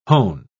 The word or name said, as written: hone